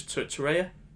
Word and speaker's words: Torreira: But anyway, with Uruguay, this Lucas Torreira. – Torreira, that's what we said.